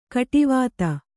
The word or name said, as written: ಗಾಡಿವತಿ